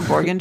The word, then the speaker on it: Borgen: It's B-O-R-G-E-N, Borgen. Like, how Borgen? Else would it, what else would it be? – Borgen. I don't know. Borgen just doesn't sound like a, you're like, I'm used to Borgstrom.